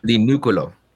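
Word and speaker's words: Di Nicuolo: I always say this wrong. Say it. – Di Nuculo.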